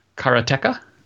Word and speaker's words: Karateka: And I can never pronounce it correctly. Because to me, my brain just reads karate. And I know it's not called karate. It's karateka, I think.